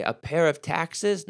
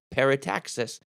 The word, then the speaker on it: parataxis: You say, a pair of taxes? No, not a pair of taxes, parataxis. Let me spell it for you. P-A-R-A-T-A-X-I-S, parataxis.